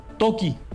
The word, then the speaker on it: Toki: Ya después de todo ese interés le pusimos Toki, uh-huh, como un walkie-talkie, pero se escribe simplemente T-O-K-I.